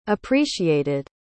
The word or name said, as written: appreciated